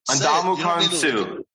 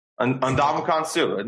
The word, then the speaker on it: Ndamukong Suh: I'm going to say it right. Ndamukong Suh. Ndamukong Suh. Ndamukong Suh. It's easy. Ndamukong Suh. There we go.